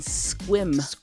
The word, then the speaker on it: Sequim: I am now in Squim. Washington. And it is spelled like Sequim. So it's S-E-Q-U-I-M.